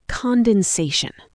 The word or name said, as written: condensation